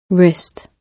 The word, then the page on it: wrist: British English